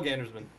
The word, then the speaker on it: Gandersman: Oh, I'm Paul Gandersman. – Yeah. – Not pronounced like that. I don't know why I said it. Paul Gandersman.